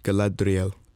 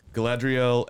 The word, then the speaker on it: Galadriel: I wussed out of trying to pronounce it. – And that made it worse. It's like Galadriel. – Yeah, there you go. You're much better. So Galadriel and then Elrond are the main two.